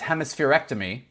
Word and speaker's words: hemispherectomy: Kind of to your point at the beginning, what the way you pronounce it is hemispherectomy.